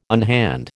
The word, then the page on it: unhand: American English